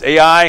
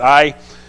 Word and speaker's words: Ai: It's Ai. I had to look up. Am I saying this right? Yep, that's how you pronounce it, Ai.